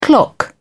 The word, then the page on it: clock in: British English